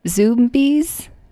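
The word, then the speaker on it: Zoombies: So I saw a trailer for a new movie that's going to be coming out, and I believe it would be pronounced Zombies, like zoo. – Okay. – And bees.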